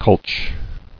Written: Cultch

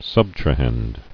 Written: Subtrahend

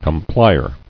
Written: Complier

